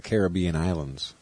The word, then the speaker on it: Caribbean: It's the Caribbean Islands.